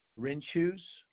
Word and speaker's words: Rinchuse: Renshoes. – Can you spell that for me? – R-I-N-C-H-U-S-E.